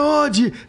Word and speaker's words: Oddi: Odd mesmo ou é, é Odd? – É Ode, Ode. Ode. O, o Silvio Lancelotti brincava comigo, não, é Odd, é Odd. Ele adorava é, corrigir as pronúncias uh -huh. né, dos jogadores italianos. Ele corrigia até a pronúncia do meu sobrenome.